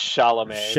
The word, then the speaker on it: Chalamet: I don't think it's Chalamet.